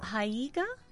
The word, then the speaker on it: haiga: The Writers' Center of Indiana has a two-session class this month that will teach you the Japanese form of haiga, which combines haiku and visual art. Invited instructor to our studio to learn more. – Haiga? Am I saying it correctly? – Haiga. Yeah.